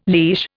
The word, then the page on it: leash: Indian